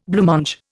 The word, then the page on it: blancmange: Indian